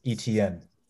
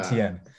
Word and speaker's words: Etienne: But I also went with Travis Etienne. That's what you pronounce his name. Ah, Etienne.